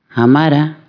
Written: martiellu

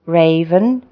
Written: raven

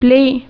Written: ankosotra

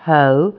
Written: hoe

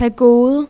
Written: معبد بودايی

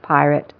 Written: pirate

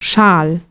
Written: Schal

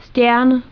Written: stidda

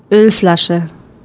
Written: Ölflasche

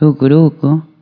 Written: a se cufunda

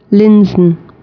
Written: Bügeleisen

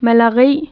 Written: gambar

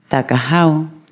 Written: hazi

